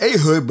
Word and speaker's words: Ehud: Pronounced Ehud, but it's spelled E-H-U-D.